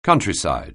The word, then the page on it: countryside: anglais